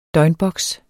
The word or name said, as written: døgnboks